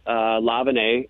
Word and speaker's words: LaVigne: Last name. LaVenay, but it's spelled like Avril Lavigne, LaVigne.